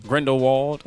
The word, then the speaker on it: Grindelwald: Grindelwald. Did I say that right? – Grindelwald has made his dramatic escape and has been gathering more followers for his cause.